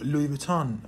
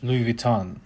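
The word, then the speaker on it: Louis Vuitton: ببینید در زبان انگلیسی هم سعی شده که ریشه های تلفظی این کلمه به فرانسه حفظ بشه، جوری که در زبان انگلیسی هم حتی شما این کلمه رو لوی ویتون میشنویدش. – لوی ویتون، بله.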